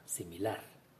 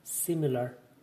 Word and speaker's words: similar: Un ejemplo de esto es la misma palabra similar la diferencia radica en que en inglés esta palabra lleva la acentuación en la primera sílaba y en español en la última. Similar, similar.